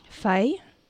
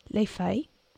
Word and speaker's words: feuille: say the word for leaves in French, Els. – Feuille. Les feuilles.